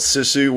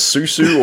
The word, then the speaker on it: Sisu: Yeah, I got to talk to director Jalmari Helander, and the film is called Sisu, which really isn't that hard to pronounce. You just pronounce it phonetically, but I think your problem is you couldn't figure out if it was, you couldn't remember it. You didn't know if it was Susu or Sisu or Sisu.